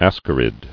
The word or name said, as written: Ascarid